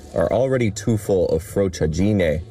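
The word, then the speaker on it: frociaggine: Stanno pronunciando tutti in modo sbagliato, non ho detto in questo modo, ho detto frociagine.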